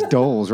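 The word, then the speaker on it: dhole: And have you ever heard, and I'm assuming this is how you pronounce it, D-holes? – Yeah, well, you're talking D-holes? To two of them right now. – Yeah, I mean, I've heard doles, yeah. I guess it's doles, right? But it's D-H-O-L-E-S.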